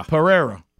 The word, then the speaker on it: Pereira: Pereira. – Think about pa, and then the way we like steak, rare. – Pereira.